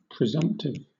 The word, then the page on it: presumptive: British English